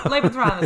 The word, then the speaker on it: Lebensraum: Lebensraum. Can you correct my pronunciation? – No, Lebensraum. Lebensraum is correct.